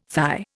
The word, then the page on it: thigh: Indian